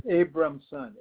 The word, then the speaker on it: Abramson: Abramson, A-B-R-A-M-S-O-N. – A-B-R-A-M-S-O-N? – Right.